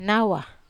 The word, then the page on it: nawa: wamesa